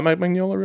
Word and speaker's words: Mignola: I didn't know anything about Mike Mignola, really. Also, that's how you pronounce his name.